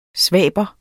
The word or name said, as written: svaber